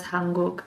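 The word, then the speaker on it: Hanguk (한국): South Korea is pronounced as Hanguk, and the word Han means big, large, wide, high, and abundant, but has a double meaning of resentment and sorrow.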